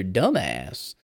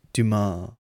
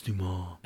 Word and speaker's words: Dumas: The Count of Monte Cristo by Alexander Dumbass." And Tim Robbins' character goes, "It's pronounced Dumas. – It's Dumas.